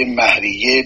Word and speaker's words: مهریه: اما مهریه، من این رو اصلاح بکنم، مهر به کار برده نمیشه، تلفظ مهر درست نیست، مه از و مهریه. همه اساتید ما و قضات دادگاه و حقوقدان ها این کلمه را به صورت مهریه بر وزن شهریه تلفظ می کنن.